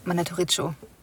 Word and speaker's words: Mandatoriccio: Mandatoriccio. Ich glaube, das I spricht man nicht aus. – Ja, danke. Danke für die schöne Korrektur. Wissen wir aus zuverlässiger Quelle, dass das I am Ende nicht gesprochen wird.